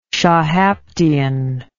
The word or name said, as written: shahaptian